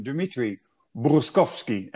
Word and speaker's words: Dmitry Burkovskiy: First of all, the introduction, Dimitri Bruskovsky. And we discussed it. I came close. So welcome, Dimitri. And I say the name right, correct? – Yep, absolutely. It's really hard to pronounce this right.